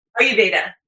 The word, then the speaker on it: Ayurveda: What is Ayurveda? Do I pronounce it right? – Ayurveda. Close. Almost. Yeah. – Ayurveda.